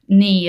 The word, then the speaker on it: knee: So, for example, you know that knee, as in the body part, is written with a K at the front, K-N-E-E, but we don't say the K out loud, and we just learnt that as part of learning English spelling.